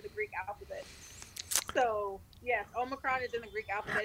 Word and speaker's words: Omicron: So listen, since we're talking about cooking and cracking and kitchens and stuff, can we talk about Omicron? Am I saying it right? – You're not. It's called Omicron.